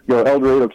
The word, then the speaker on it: El Dorado: It's one of those things when you live in the town, you kind of used to the pronunciation. So, El Dorado, Kansas, and Butler County Community College.